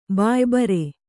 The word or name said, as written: ಬಾಯ್ಬರೆ